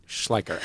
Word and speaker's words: Schleicher: Well, my family has always gone with Schleicher rhymes with biker. – Schleicher, okay. – But when I talk to like a German person, it's like Schleicher.